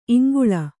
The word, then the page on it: ಇಂಗುಲೆ: kannada